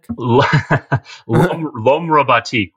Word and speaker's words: L'Homme Robotique: The tracks are floating around on YouTube because is it pronounced L'Homme Robotnik? – L'Homme Robotique, which is like not French for robotic man.